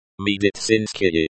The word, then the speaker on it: медицинский: Medicinsky is pronounced with a stress on the third syllable, Medicinsky.